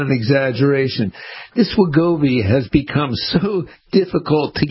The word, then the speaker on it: Wegovy: So there's information about is it Wegovy? Is that how it's pronounced? – Yes.